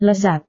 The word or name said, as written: lezat